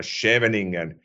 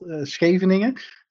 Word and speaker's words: Scheveningen: So for me, as a Finn reading Dutch, I can read this as a Scheveningen, and I'm fairly certain it's not pronounced so that somebody from the Netherlands would say, oh, you sounds like a fluent Dutch now. So please introduce yourself and make sure to tell us how to pronounce this word. – Well, it's called Scheveningen.